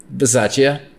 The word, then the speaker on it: Bisaccia: Bisaccia. Did I butcher that? – Bisaccia. – Bisaccia.